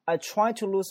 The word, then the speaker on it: tried: 我们首先来看这里面的发音部分，他说 I try to lose myself in work. 首先这个 T-R-I-E-D 叫 tried, 那个 D 怎么没有了呢？因为在一个发音里面 D 或 T 结尾经常就失去发音了，所以那个 tried 那个 D 根本听不到，它就是 I try to lose myself in work, I try to lose myself in work, but every time I stop to think, but 这个 T 可有可无，but every time I stop to think, but 那个 T 啊可有可无。